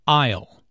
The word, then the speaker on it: aisle: Aisle. Notice the silent S in aisle.